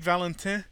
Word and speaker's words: Valentin: Anyway, in the very first Father Brown mystery, you get this trifecta of Father Brown and the police detective, and I get to pronounce this in French too, Aristide Valentin.